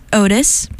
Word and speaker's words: Ohtis: The record is called Curve of Earth, and this is Otis, but if you're looking for them, it's spelled O-H-T-I-S.